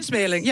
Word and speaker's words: Smeerling: Ja, we zijn op Smeerling, zeg ik het goed? – In Smeerling, ja, in Smeerling, klopt.